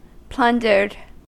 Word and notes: plundered: American English

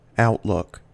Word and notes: outlook: American English

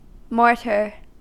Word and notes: mortar: American English

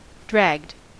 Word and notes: dragged: American English

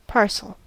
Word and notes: parcel: American English

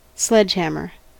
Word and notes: sledgehammer: American English

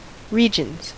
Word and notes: regions: American English

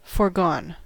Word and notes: foregone: American English

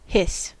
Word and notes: hiss: American English

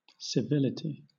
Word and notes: civility: British English

